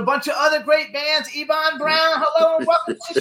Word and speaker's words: Eban: So that's how we pronounce it, Eban Brown, right? – Eban Brown. – Eban. Okay. Okay. See, we wanted to get it right. – I would have pronounced it Eben. – Eben. Well, you can do that. Eben, Eban, or Eben.